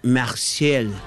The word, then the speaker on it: Marseille: Y Marsella se pronuncia Marseille. Marseille. ¿Así será, che? – Así te va a hacer.